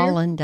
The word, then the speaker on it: Haaland: The Native American woman, Miss Deborah Haaland, did I pronounce it right? Department of Interior. – Haaland, I think. H-A-A-L-A-N-D.